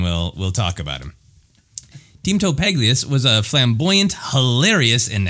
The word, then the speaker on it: Pagalies: It's Teemto, T-E-E-M-T-O, and then Paglius is how I'm saying it. P-A-G-A-L-I-E-S. – Oh, trust me, that comes up.